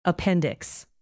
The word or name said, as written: appendix